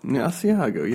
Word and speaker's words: Asiago: A lot of words I can't pronounce. Asiago? – Asiago, yes.